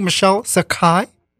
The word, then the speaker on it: Michelle Sakhai: And I hope I pronounced your name correctly, Michelle Sakhai.